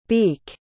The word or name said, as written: beek